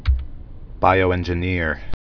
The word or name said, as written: bioengineer